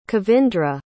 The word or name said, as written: kavindra